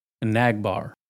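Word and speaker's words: Enagbare: Am I saying that right? Enagbar. Nag. Nagging. Got this nagging toothache. There's this nagging lady at the bar. It's a bar full of nags. I'm in the nag bar.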